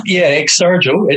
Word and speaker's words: Exsurgo: Is that how you pronounce it correctly? – Exsurgo. It's Latin Exsurgo. For to stand and to rise, to recover, which we thought was very appropriate, but nobody can pronounce it. I think the Italians pronounce it Exsurgo. We say Exsurgo.